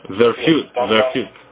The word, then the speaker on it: Verfuehrt: How do you pronounce it? Verfuehrt? – Yeah, Verfuehrt. – Verfuehrt.